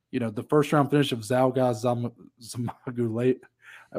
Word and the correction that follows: Zhumagulov: always forget how to pronounce this guy's Zhumagulov.